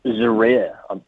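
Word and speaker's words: Djere: Casper Ruud going out of this tournament, getting beaten 6-3, 3-6, 7-6 by Laszlo, how do you say his last name, Dejere, is it? – Zaire, we think.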